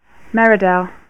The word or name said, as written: Meridell